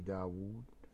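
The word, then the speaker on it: داود: ولكننا نستطيع أن نضرب المثل هنا عفوا بالحروف التي لا تنطق كالواو التي في عمرو، والحروف التي لا تكتب كواو المد في داوود، والحروف التي تنطق ويكتب رمز غيرها من الحروف كالألف في رمى وهل مجرة.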